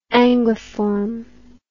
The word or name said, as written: anguiform